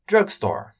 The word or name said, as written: Drugstore